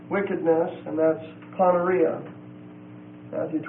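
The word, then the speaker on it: Poneria (πονηρία): The next word is the word Poneria. P-O-N-E-R-I-A. P-O-N-E-R-I-A. It's a long E. The word means wickedness. Comes from the word poneros, P-O-N-E-R-O-S.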